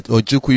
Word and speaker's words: Ojukwu: Ojukwu, you get that one.